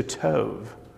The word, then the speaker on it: Tov: And if you want to know how to pronounce that little title there at the top of your bulletin, it's from Tohu Vavohu to Tov.